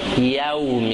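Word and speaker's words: يوم: افسوسه که زیاده لوگان غلط تلفظ کرنگه کهته یه مالک یوم یو نهی یو یا که اوپر فتح زبر هست پیش نہیں ہے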